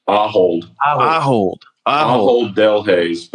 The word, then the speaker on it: Ahold Delhaize: I can never say this business name, so y'all help me here. Is it Ahold Delhaize? – Ahold Delhaize, but Ahold is sufficient. – Okay. That brings to mind my constant emphasis on the wrong syllable. So, Ahold Delhaize.